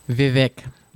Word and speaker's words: Vivek: Vivek. That's the way you pronounce his name.